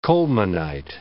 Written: colemanite

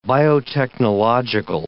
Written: biotechnological

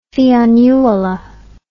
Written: fionnuala

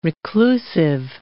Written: reclusive

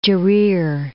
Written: Jarir

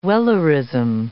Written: Wellerism

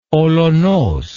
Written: olonos

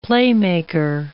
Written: playmaker

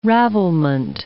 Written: ravelment

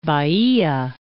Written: Baia

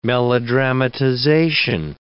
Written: melodramatization

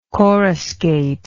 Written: coruscate

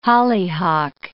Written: hollyhock

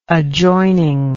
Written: adjoining

